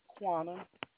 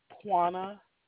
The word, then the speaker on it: Quanna: Quana. – What is it? – Quana. – How do you spell that? – Q-U-A-N-N-A.